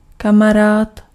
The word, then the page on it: ystävä: /ˈys.tæ.væ/